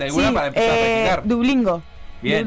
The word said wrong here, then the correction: Duolingo: Dublingo dublingo ah. Duolingo. Duolingo, sí, es bueno Duolingo.